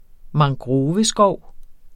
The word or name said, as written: mangroveskov